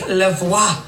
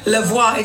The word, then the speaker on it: La Voix: My name is Lavoie. Lavoie. It's like voila, but backwards. Lavoie. So that's how you say it.